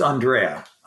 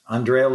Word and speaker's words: Andrea: It's Andrea. Andrea Lorenzo Molinari.